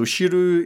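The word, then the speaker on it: shiru (汁): So shiru it's a you know mm-hmm. character mm. for soup, uh, and it's pronounced shiru.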